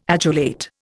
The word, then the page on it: adulate: Indian